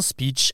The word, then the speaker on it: speech: Pour un anglo-saxon, ces deux mots sont impossibles à confondre car ils ne se prononcent pas du tout pareil. Le premier s'écrit SP2ECH, speech, et l'autre PITCH, pitch. Sauf que nous, les Français, nous prononçons les deux avec le son I, un speech et un pitch.